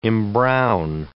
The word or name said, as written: embrown